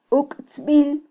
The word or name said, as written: uktzbil